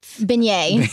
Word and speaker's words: beignet: Beignet.